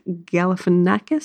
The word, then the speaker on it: Galifianakis: It stars Oprah Winfrey, Reese Witherspoon, Mindy Kaling, Gugu Mbatha-Raw, Michael Pena, Storm Reid, Zach Galifianakis, is that how you pronounce it? – Yeah.